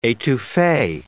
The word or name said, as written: etouffee